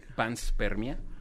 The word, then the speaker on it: panspermia: Panspermia. – Ajá, panspermia o vans. – Pans, eh, como de pants, como de pantalón, spermia.